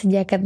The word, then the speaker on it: Soe Jagat: Bernama tulisannya Soe Jagat gitu ya, padahal bacanya sejagat nih.